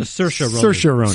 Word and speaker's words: Saoirse: I don't know how to pronounce her name, but the Irish actress, something Ronan, from Saoirse Ronan.